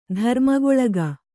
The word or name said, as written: ಧರ್ಮಗೊಳಗ